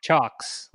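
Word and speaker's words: Cioks: The correct Chox. Pronunciation is Chox, like a chalkboard.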